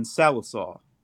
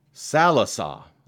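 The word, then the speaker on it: Sallisaw: Now, your congregation in Oklahoma, did I say that right? – It's a Trinity in Salisaw. – Salisaw. Okay, very good.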